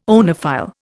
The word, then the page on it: oenophile: Indian